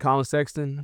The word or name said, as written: Collin Sexton